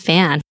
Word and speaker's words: Phan: My middle name is Tan and my last name is Phan.